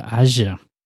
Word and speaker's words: Aja: How do you pronounce it? Again, Aja. We've already given up. Is it Aja? – Yes. – Alexander Aja.